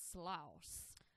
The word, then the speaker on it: Sloss: And one of the guys that founded Birmingham, Colonel James Withers Sloss. – That felt like the correct way to say that.